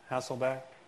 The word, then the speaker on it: Hasselbeck: Hasselbeck.